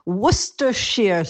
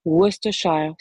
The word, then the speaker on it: Worcestershire: Bonjour, ça se prononce Worcestershire sauce. Worcestershire. – Quelle équipe, on va quand même corriger cette prononciation, ça va pas du tout. Worcestershire sauce. Je répète. Worcestershire sauce.